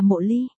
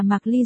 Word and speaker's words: Mộ Ly: rong, một bộ nhỏ bên dưới, nên có khả năng M.U. Ly phải đọc là Mộ Ly. Cơ mà xuất từ đầu chuyện đến giờ toàn để là Mạc Ly rồi nên tôi cố định sửa lại nữa.